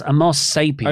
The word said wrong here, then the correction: Homo sapiens: Homo Sapiens. Come on, mate.